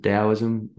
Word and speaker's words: Taoism: which is spelled with a T, like Taoism, but they pronounce it Taoism with a D, I guess.